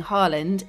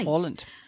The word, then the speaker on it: Haaland: We mentioned this briefly on last week's podcast because, Lynsey, it's no longer Erling Haaland, it's Erling Haaland. That's right. Haaland.